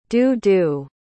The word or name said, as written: doo-doo